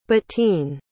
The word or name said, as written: Bettine